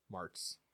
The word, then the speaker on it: Martz: You could say his name right to start. – What is it? – Martz.